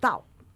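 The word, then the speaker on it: tao (到): Estos tres tao que hemos comentado, que se pronuncian en cuarto tono, ¿vale? Como enfadados, ¿vale? – Todos igual. Todos, tao.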